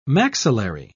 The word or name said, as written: maxillary